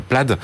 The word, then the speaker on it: Plaid: donc la Plaid. C'est comme ça qu'on prononce en anglais, ça s'écrit Plaid.